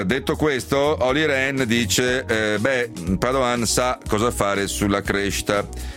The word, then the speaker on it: Padoan: Dall'Europa è arrivato comunque un plauso ben augurante nei confronti del neo ministro dell'economia Piercarlo Padoan, o Padoan, insomma, dipende se vogliamo pronunciarlo alla veneta o no, perché lo ha detto lo stesso commissario agli affari economici Olli Rehn.